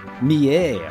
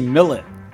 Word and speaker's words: Millet: What's her last name? – Mie. – Oh. That's I was going to say Millet. – It's spelled like Millet. – It is spelled like Millet. – Yeah. Mie. So that's French, right?